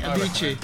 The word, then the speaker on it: Avicii: Avicii.